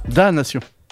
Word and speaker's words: damnation: Il y a un M et un N, là. – Bah, oui, ça bah, on prononce pas. – Le me, on le prononce pas, me. – Ok, bah, Damnation. – C'est un me muet. C'est une vache qui parle pas.